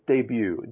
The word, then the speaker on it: debut: Just remember it is pronounced debut, D-E-B-U-T. We don't say the T on the end, debut.